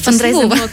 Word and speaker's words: фандрайзинг: Плюс я знаю, що у вас є фрейд, е, фрейдрайзинг, але я мені важко вимовити. – Так, фандрайзингова компанія.